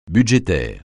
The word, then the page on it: budgétaire: francais